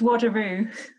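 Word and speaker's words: Wado-ryu: So I'd like to introduce a black belt in Wadurai. Is that how you say it? – It's Wadurai. And that's how it's spelled, yes.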